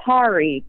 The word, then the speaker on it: Kari: It's actually Kari. Kari like Ferrari is what I usually tell people. – Nice. – They get mispronounced a lot. It's a weird Swedish name. So if you can just think Kari like Ferrari, that'll help you pronounce it.